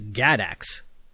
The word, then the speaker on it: GADACS: Next was an experiment with an acronym that I'm going to pronounce as GADACS. That's G-A-D-A-C-S.